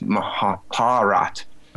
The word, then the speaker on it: Mahabharat: I had someone um, who's a Sanskrit scholar when I said, "Oh, the Mahabharat, right? You don't say the last a. The a is silent." I thought I was so so advanced. And he said it's Mahabharat.